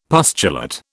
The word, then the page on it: pustulate: British English